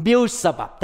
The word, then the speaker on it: Beelzebub: Actually, the word busabab I don't know in English. I think I may say it wrong in Beelzebub. Beelzebub. Thank you. My pronunciation, Beelzebub.